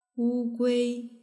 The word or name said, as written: wugui